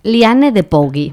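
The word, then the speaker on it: Liane de Pougy: Que convirtió a Anne-Marie en una bailarina de primera línea de cabaret, y a partir de ahora ella sería conocida como Lion de Bruy. Lo hemos expuesto antes en el Google de este, ¿lo he dicho bien? – No. – Lion de Bruy. – No, pero sigue. – Liane de Pogui, para los que quieran googlearla, ¿vale?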